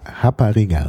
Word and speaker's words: Rapariga: Also Portugiesisch Rapariga, so ist ausgesprochen, das R wird ja wie H ausgesprochen im Brasilianischen, ist in Brasilien die Prostituierte.